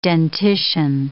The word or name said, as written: dentition